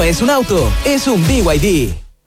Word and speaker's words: BYD: ¿Los VID? – ¿VID? ¿No es BYD? – Nada que ver. Se escribe BYD, pero se dice BYD.